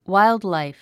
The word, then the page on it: wildlife: wáildlàif; ワイルドライフ